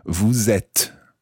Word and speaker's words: vous êtes: So that's E circumflex T-E-S, vous êtes. And notice that when you follow vous with et, you pronounce the S of vous, vous êtes.